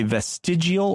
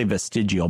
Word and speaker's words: vestigial: You could say vestigial or vestigial, and people say it two different ways. I'm going to say vestigial, but Barry wants to know, like, your appendix is a maybe not.